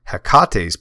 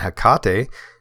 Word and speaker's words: Hecate: I looked in Dion Fortune's book called Mystical Kabbalah and Hecate. Am I saying that right? It sounds like almost like an Asian name, Hecate.